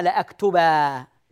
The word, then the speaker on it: لأكتبا: نكتب نون التوكيد الخفيفة كما نكتب تنوين النصب بالألف ونقرأها إذا وقفنا ألفا إذا وصلنا نقرأها نونا يعني لما أقول أكتبا درسي هنا أنطقها نون لكن إذا وقفت أقول لأكتبا لأكتبا